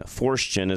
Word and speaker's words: Forstchen: And it's Forstchen, Forstchen. Forstchen is how it's pronounced, but Forstchen.